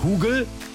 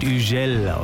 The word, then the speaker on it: Hugel: Das ist ein französischer DJ, deswegen heißt er nicht Hugel, so wird er nämlich geschrieben. Er wird Hugel ausgesprochen und schon klingt's viel cooler.